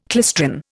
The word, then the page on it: klystron: Indian